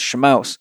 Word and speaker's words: Shamos: I'd also like to say I have a podcast that'll be coming out in the next 2 months, probably with Greg Shamos, actually pronounced Shamouse.